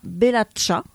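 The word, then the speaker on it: Belatxa: Alors, Aranoa Eta Belacha. J'espère que je dis bien le, prononce bien cha. – Le bas. – Ouais, TX, cha. Cha. – Voilà. – Ouais, cha, cha.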